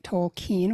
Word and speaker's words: Tolkien: Yes, I'm saying it Tolkien, which is apparently the correct pronunciation. I've been saying Tolkien for many years, but was recently corrected.